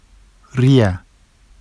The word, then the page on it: rija: Vafsi